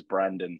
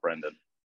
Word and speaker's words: Brandon: And I can't play it here because it doesn't seem to work, but his name is Brandon Steele. It's not Brendan.